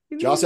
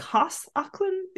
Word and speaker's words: Joss Ackland: Or like Hoss Ackland? Isn't he? He feels very German. Hoss Ackland. You don't pronounce J's in German. I don't know. – Yeah, Joss, I guess. It's Joss. A soft J.